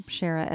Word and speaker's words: Shera: One of those that I go up to people and they'll ask my name, Shara, S-H-E-R-A, Taylor.